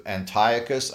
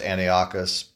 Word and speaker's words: Antiochus: It can apply to Antiochus. I used to call this Antiochus, but that's not the way you pronounce it. It's Antiochus.